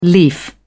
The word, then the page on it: leaf: British English